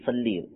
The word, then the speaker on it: صلی: تو عربی تلفظ جو ہے وہ اللہم صلی یعنی لام کے نیچے زیر ہے, اور اردو میں جب اس کو ہمارے یہاں پڑھا جاتا ہے تو اس میں صلی کی جگہ صلے پڑھ دیتے ہیں.